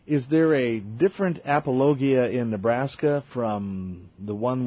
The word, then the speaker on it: apologia: I think it's the Greek word apologia.